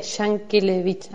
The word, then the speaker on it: Yankelevich: Bien, Yankelevich.